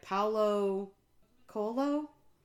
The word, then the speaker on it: Paulo Coelho: So for not next episode, but the episode after that, we are going to be reading The Alchemist by Paulo Coelho? – Yes.